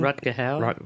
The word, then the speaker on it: Rutger Hauer: It's a Rutger Hauer movie. Did I pronounce his right name? – Rutger Hauer.